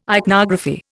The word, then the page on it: ichnography: Indian